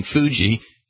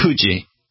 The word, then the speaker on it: Fuji: but the closest noise phonetically that the Japanese have to an F, they actually blow through pursed lips. So instead of saying Fuji, you would say Fuji, like that.